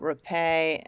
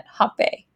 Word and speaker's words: rapé: So we know that you also work with forest medicines, and wanted to see if you can tell us about Rapé and Sananga specifically. Is that how you pronounce it? – Yeah, there's depending on, and Peru, they'll call it rapé, and in Brazil, they'll call it hopé.